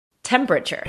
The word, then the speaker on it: temperature: La palabra temperatura en inglés se pronuncia, inglés americano, por cierto, temperature.